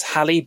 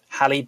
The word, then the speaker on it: Halle: And it's starring, is that Hallie? H-A-L-L-E. – Yes. – I don't know how you pronounce her name. So it's Hallie Bailey, which is interesting because obviously it's very like Halle Berry, but Hallie Bailey is starring.